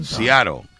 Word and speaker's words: Seattle: Seattle, así se pronuncia.